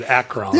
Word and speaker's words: Akron: Did I say Akron? Like, you did. – To rhyme with Dacron? – You did. Akron.